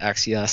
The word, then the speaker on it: Axios: And Axios, which I think is actually pronounced Axios, so apologies to them.